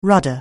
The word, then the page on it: rudder: anglais